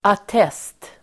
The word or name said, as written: attest